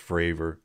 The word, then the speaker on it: Fravor: I mean, they ended up having, I know I expressed my displeasure about having only the two Graves and Favor. I was mispronouncing it's Favor. It's, what is it? – Fravor? – Fravor. Yeah. Yeah, it's Fravor.